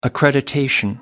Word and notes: accreditation: British English

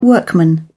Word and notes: workman: British English

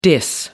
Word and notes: diss: American English

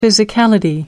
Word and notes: physicality: American English

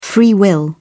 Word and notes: free will: British English

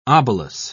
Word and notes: obolus: British English

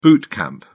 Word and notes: boot camp: British English

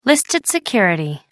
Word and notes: listed security: American English

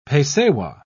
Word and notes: pesewa: British English

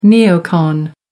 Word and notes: neocon: American English

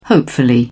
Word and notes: hopefully: British English

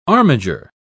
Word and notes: armiger: British English

